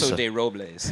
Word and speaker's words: Paso de Robles: Paso de Robles is how we say it.